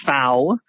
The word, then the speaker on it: FAO: So the Food and Agriculture Organization, which we pronounce the FAO, rhymes with now,